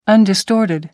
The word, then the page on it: undistorted: American English